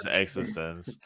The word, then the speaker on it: eXistenZ: eXistenZ, then maybe. But no, I think it's because they made it, is, it is eXistenZ.